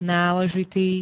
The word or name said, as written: náležitý